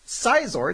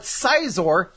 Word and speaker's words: Scizor: But upon looking up a pronunciation guide for Pokemon names, I recently discovered it's not Scizor, it's Scizor. – Yeah. – And that's nonsense. That sounds ridiculous. – If they wanted it to be pronounced Scizor, it would stay like S-C-Y, like Scyther is.